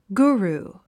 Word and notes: guru: ɡúːruː; グゥルゥ

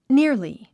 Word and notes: nearly: níə r li; ニアリィ

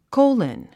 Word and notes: colon: kóulən; コウロン

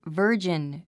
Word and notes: virgin: və́ː r dʒin; バージン

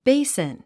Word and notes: basin: béisn; ベイスン

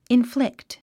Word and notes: inflict: inflíkt; インフリクト

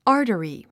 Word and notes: artery: άː r t ə ri; アータリィ

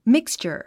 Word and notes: mixture: míkstʃə r; ミクスチャァ

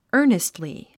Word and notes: earnestly: ə́ːrnistli; アーニストリィ

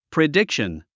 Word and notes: prediction: pridíkʃən; プリディクション

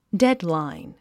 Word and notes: deadline: dédlàin; デッドライン

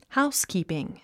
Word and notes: housekeeping: háuskìːpiŋ; ハウスキーピン

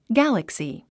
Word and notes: galaxy: ɡǽləksi; ギャラクスィ

